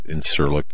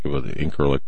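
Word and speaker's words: İncirlik: And some pronounce it Incirlik, Incirlik, whatever.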